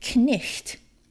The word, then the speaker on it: knight: So if you had the word knight, as in knights on a horse, back in the day they would have spelled it K-N-I-Y-O-T and you'd pronounce it knicht.